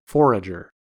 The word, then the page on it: forager: American English